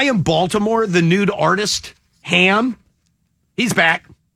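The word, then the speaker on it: Hamb: The guy's name is Ham. It's H-A-M-B. The B is silent.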